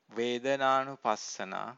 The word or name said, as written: vedananupassana